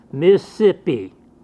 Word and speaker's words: Mississippi: You notice how Mississippians don't use 4 syllables for Mississippi. They use 3. They drop one. Mississippi. Not Mississippi. They're tired of saying it, I guess, and so they just drop one of them.